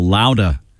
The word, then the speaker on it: Loudah: Sal Baglio, formerly of the Stompers, has a new band called the Amplifier Heads and a great album just released called Louda, L-O-U-D-A-H, as in the New England pronunciation of the word louder.